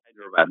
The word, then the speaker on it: Hyderabad: Hyderabad? Am I saying that right? Hyderabad? – Hyderabad, that's right.